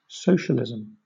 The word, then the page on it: socialism: British English